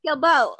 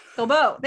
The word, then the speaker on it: Guilbaut: I still don't know how to say his last name. Guilbaut. Guilbaut. Thank you.